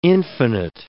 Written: infinite

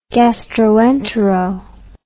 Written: gastroentero

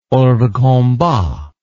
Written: hors de combat

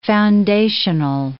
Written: foundational

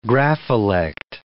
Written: grapholect

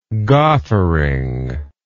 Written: goffering

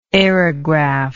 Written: aerograph

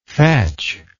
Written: fetcher

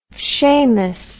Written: shammus

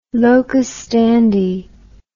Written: locus standi

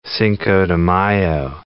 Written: Cinco de Mayo

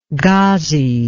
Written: ghazi

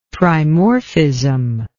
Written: trimorphism

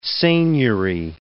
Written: seigniory

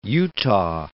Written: Utah

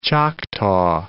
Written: Choctaw

